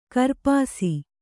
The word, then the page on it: ಕೂರ್ಪಾಸ: kannada